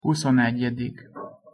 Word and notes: huszonegyedik: Hungarian

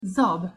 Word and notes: zab: Hungarian

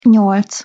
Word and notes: nyolc: Hungarian

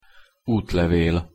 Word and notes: utlevel: Hungarian

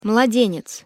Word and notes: mladenec: Russian

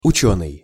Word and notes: ucenyj: Russian

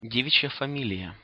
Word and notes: devic'ja familija: Russian

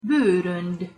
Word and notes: borond: Hungarian

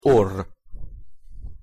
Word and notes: orr: Hungarian

